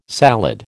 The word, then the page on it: salad: American English